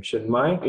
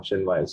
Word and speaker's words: Chinmaya: Chinmaya. In case if they do, it's like my chin, the other way around, Chinmaya. So that's me.